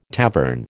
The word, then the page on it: tavern: American English